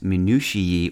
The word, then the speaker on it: minutiae: In English, minutia is most often used in the plural, spelled M-I-N-U-T-I-A-E, pronounced minutiae or minutiae, or sometimes spelled M-I-N-U-T-I-A as the singular is.